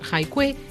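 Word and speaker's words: hai Kui (海归): Por coincidencia, en chino, la pronunciación hai Kui es homófona a la de un animal acuático, la tortuga marina.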